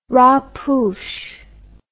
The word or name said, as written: rapoush